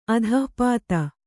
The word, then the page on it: ಆತಪ್ತ: ātapta